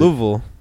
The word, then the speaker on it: Louisville: You're supposed to like say it in two syllables. Like Louisville.